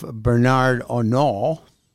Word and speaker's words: Bernard Arnault: And LVMH, which is the richest man in the world, a guy by the name of Bernard Arnault.